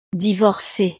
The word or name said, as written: divorcee